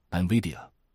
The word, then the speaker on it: NVIDIA: 真的，哪怕 10次里只做对 5次，只要你的投资组合里有好事多，沃尔玛或是我念不准发音的英伟达 NVIDIA，不过我快学会正确发音了。